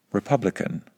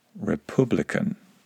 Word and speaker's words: republican: Other words include publicity, not publicity, and republican, not republican.